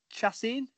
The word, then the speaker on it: Chacin: We've got Arrieta versus, is it Chacin? Is that you say his name? – Yep.